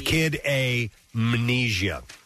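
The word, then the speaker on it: Kid A Mnesia: So, yeah, Amnesia is spelled M-N-E-S-I-A. That's why I'm saying it like yeah. – that. – Yeah. – It's Kid Amnesia.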